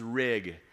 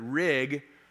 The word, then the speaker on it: Rigg: My last name is Rigg, And, uh, you know, growing up, it didn't take long at all for kids to figure out that Rigg rhymes with pig.